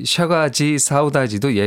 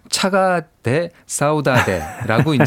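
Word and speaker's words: Chega de Saudade: 되도록이면은 이제 원어에 맞게 이제 발음을 하고 있는 경향입니다. 그래서 아, 이 샤가지 사우다지도 예전엔 차가대 사우다대라고 이제 이렇게 발음 나는 대로 그렇죠. 영어 어, 발음 나는 대로 표기법대로 네. 이렇게 했습니다.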